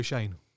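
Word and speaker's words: Schoen: That's not how you pronounce Shane. – Schoene. – I checked the spelling on that one as well. – Joe Shane. It is Shane.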